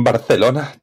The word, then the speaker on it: Barcelona: La C en Barcelona te ha salido, pero como a una nativa.